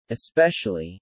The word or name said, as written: especially